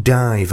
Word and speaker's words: DIIV: I listen to the show religiously, but I don't think I've ever heard you play anything by D-I-I-V, pronounced Dive, apparently.